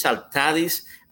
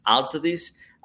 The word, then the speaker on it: Altadis: It was a combination when the Spanish tobacco company, Tabacalera, joined or merged with the French company called CITA, C-I-T-A, and together they met, they came out with Altadis. So in Spanish it's Altadis, in English because of the accents Altadis, I still call it Altadis, and that's the way I pronounce it, but you know, we'll go for both ways.